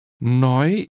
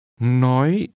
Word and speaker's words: Nói: Nói. Nói. Nói. – This is pronounced with a high rising tone. Say again, speak. – Nói.